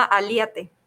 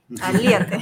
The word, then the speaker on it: Aliate: Claro, este, el consultor en donde estoy trabajando de mi equipo se llama Aliate, con acento en la I. – Aliate.